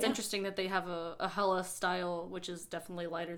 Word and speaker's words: Helles: Helles, spelled H-E-L-L-E-S, pronounced H-E-L-L-U-S.